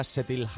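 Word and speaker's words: Kjetil: Pues el batería, Setil Halre, que es noruego. – Bueno, pues de cómo se dice a cómo se escribe, que va con una K y una J.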